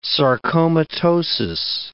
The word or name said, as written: sarcomatosis